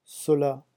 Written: cela

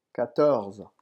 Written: quatorze

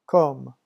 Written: comme

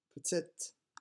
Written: petite

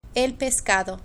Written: el pescado

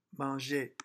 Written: manger

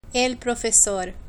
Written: el profesor

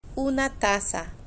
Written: una taza

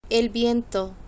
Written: el viento

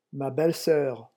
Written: ma belle soeur

